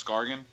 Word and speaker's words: Scargan: But the book itself is about Thomas Scargan. It's a hard G, by the way.